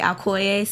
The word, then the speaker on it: Alcoye: Because people can't pronounce my name, like Alcoye, so they just call me Al. So. – Guys, it's like Kanye, but Alcoye.